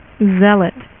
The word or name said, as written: Zealot